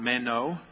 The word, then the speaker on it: meno: And in Greek, that word is pronounced meno.